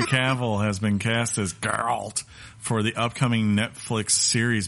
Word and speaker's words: Geralt: That's how you say it, by the way. Geralt.